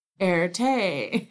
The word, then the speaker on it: Erté: The name Erte was the phonetic pronunciation of his initials. Arte. Erte.